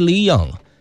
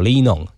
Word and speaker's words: Linion: 官方发音好像是 Leon。但是粉丝也叫他黎农哈，黎农应该是怎么样发音都可以吧。